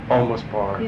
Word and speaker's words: Olmos Park: Is it Olmos Park? – Olmos Park. – Yeah. How do you spell that? – O-L-M-O-S.